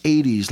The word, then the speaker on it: Aedes: It's spelled capital A-E-D-E-S, and it's pronounced 80s, like we're on WTBQ. Think of hits of the 80s, but that's how it's presented. it's hits of the 80s, you know, but 80s, it's with an A, and that's a specific mosquito where it's transmitted, and the mosquitoes are aggressive daytime biters.